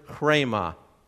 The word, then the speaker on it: rhema: Instead of logos, it's the Greek word rhema, spelled R-H-E-M-A. Pronounced what? Rhema. And we almost have to trill it off our tongues, but it comes out rhema, the rhema.